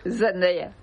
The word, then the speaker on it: Zendaya: ¿Cómo se dice Zendaya? – Zendaya. – Zendaya. – Lo dijo ella.